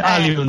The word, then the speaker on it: Allium: En lugar de Allium, Allium, porque Allium es latín realmente.